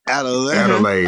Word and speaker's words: Adelaide: Adelaide. Like Kool-Aid. – I knew I was saying it wrong. – Like, yeah. Like, do you want to add another flavor to this Kool-Aid? Yeah, Adelaide to it.